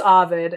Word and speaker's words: Ovid: Well, Google was like, it's Ovid.